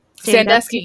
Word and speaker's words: Sandusky: Did I say that correctly? – Sandusky, yes.